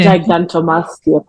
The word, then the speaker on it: gigantomastia: gigastomia, did I pronounce it correctly, foundation? – Gigantomastia Foundation.